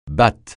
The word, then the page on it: batte: francais